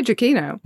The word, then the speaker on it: Giacchino: How do I say it? Giacchino? – Yeah. I feel like you say Giacchino.